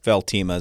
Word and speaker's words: Veltima: I know one fungicide I'd heard about, I'm probably not going to pronounce it correctly, was Veltima. – Like, yep, Veltima is the new one.